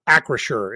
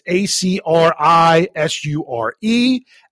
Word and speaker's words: Acrisure: Uh, I'm going to go with what I believe it to be Acrisure Stadium. Acrisure is what I am assuming the pronunciation to be. That is A C R I S U R E.